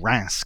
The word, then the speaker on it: Reims: Reims Cathedral, or Reims Cathedral, if you want to pronounce it that way, in France, the coronation church of the kings of France.